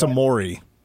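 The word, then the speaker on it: Omoruyi: Well, if they could get anybody, the big from Rutgers, Clifford Omarua, I'm probably butchering his last name. – I believe it's Amori. – Okay, Amori, yeah.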